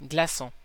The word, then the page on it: glaçon: /ɡla.sɔ̃/